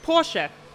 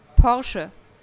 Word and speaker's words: Porsche: Because in the US, we say Porsche, and I know that's wrong. – Oh, that's true. Yeah, we always pronounce the E at the end. Okay. Not like in English. You don't pronounce the E at the end. In German, we do.